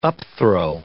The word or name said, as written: upthrow